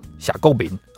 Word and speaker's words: 謝國民: 那他们是潮州人，所以谢国民这个名字你要用潮州话来发音哦，可能是夏构民哦，应该是这样子啊。